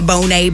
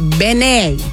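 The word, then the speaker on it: Benét: But he corrected everybody recently on his Instagram by saying it is Benet. It is Eric Benet. So just in case you didn't know, that's the correct way to pronounce his last name.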